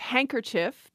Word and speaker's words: handkerchief: The students also proposed handkerchief because they said nobody pronounces the D in that.